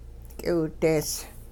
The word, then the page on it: k'iwidees: hupa